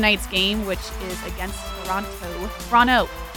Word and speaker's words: Toronto: I pronounce the second T so much so. – Yeah, it doesn't get pronounced. Toronto.